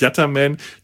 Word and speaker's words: Yattaman: Yattaman schreibt sich in Italien Yattaman, wird auch eher Yattaman ausgesprochen. In allen anderen Versionen ist es Yatter mit ER, komisch.